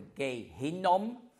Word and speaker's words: Gehinnom: Gehenna ist einfach griechisch, so ein bisschen griechisch ausgesprochen, das hebräische Gehenna. Und das heißt Tal Hinnom. Gehenna oder Gehinnom. Das ist ein Tal außerhalb der Altstadt von Jerusalem.